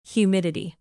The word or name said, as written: humidity